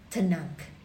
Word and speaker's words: Tanakh: So they took the first letters of each, T, N, and K, and then they pronounce it as Tanakh, okay?